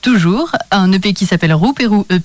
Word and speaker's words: Rouperou: c'est ce qu'on écoute à présent et c'est signé Roupirou et ça s'écrit exactement comme je le prononce. R-O-U-P-E-R-O-U. Et c'est signé, euh, ben, signé Roupérou sur leur album Roupérou EP, ou leur EP qui s'appelle Roupérou EP.